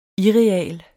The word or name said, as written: irreal